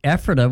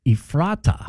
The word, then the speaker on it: Ephrata: And Kathy, checking in, Kathy Logan, she said a lot of people not from this area say Ephrata with the long E, Ephrata.